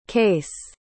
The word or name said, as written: kayce